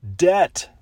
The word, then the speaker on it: Debt: Debt. D-E-B-T. The B is silent.